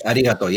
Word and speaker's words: arigato: So arigato is how we pronounce that, right? – Yes, arigato, yes.